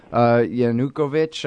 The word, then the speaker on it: Yanukovych: Or don't tell me they're protesting that badly for a trade deal, or they're just there against, this is their opportunity to oppose Yanukovych, I can't say it right. – Yeah, no, that's right.